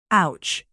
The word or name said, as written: ouch